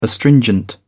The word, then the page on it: astringent: British English